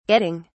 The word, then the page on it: getting: British English